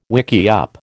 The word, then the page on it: wickiup: American English